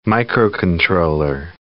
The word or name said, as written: microcontroller